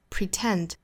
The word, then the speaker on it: pretend: Even though I pretend, pretend, 前面是 pre, 后面是 tent, pretend, pretend, 重读在后面。